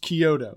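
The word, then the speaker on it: Chiodo: I've heard it pronounced Kyoto. – Kyoto, okay. – Like the city in Japan.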